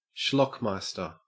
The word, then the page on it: schlockmeister: Australian English